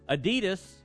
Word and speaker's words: Adidas: Adidas. – Oh, yeah? – It's not Adidas. It's Adidas.